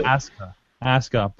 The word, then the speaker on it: Asuka: Even after just don't pronounce the U. – Asuka.